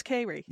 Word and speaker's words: Kayri: And Kay, by the way, and it's spelled, her first name is, and how do you, Kayri, is that how you pronounce it? It is Kayri.